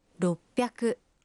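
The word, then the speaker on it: «Роппяку» (ろっぴゃく): 600… «Роппяку».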